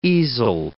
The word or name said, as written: easel